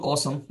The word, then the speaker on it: Osome: So it's called Awesome. It's spelled differently, but it's Awesome.